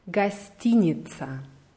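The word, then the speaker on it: гостиница: Gastinica. Die Betonung ist auf I hinter T. Gastinica.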